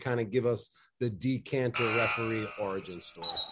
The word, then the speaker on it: Dikembe: It is a common mistake. It really is. It's like there is a second N there. It's Dikembe.